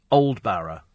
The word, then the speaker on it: Aldborough: Dean says, you must go to Aldborough, pronounced Oldborough. Oldborough.